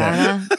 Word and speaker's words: Guaraná: You were really pronouncing the fuck out of that. – Guaraná Antarctica. – You'll follow un poco your Portuguese. – Antarctica. Oh, okay. – Wow, that's right. Impressive.